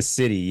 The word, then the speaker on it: Siddhi: The Siddhi? – Yeah. – Am I saying it right? – Yeah, the gift, it's the shadow? – It's the Siddhi, yes.